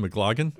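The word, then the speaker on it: McLagan: Is that how you pronounce his name? McLaughlin? Yep.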